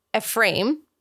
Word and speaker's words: Ephraim: And so at the direction of his handler, Ephraim, yes, that is how you pronounce it, to give the Israeli government plausible deniability, Kaufman, so Avner, has to resign from Mossad, which is...